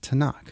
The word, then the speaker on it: Tanakh: And together, the T-N-K is pronounced Tanakh for simplification.